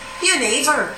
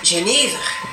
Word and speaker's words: Genever: In Dutch, je neighbor. – Je neighbor. – In Flemish, Genever.